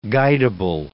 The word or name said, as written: guidable